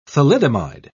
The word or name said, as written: thalidomide